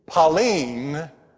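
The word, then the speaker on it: Pauline (πάλιν): But in the Greek, Pauline, that's the way you pronounce it in the Greek, not Palin, but Pauline, is the word that we use in the English language for again.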